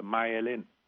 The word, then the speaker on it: myelin: Thì tiếng Anh người ta đọc là myelin.